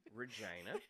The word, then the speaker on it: Regina: Okay. Regina. Rhymes with one of my favorite things.